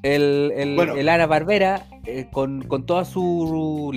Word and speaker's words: Hanna: de los Ana Barbera. ¿eh? Sí, claro. – Ana Barbera. – Hanna Barbera, weón. Hanna. – Puta, ¿la H se pronuncia aquí en Chile? No.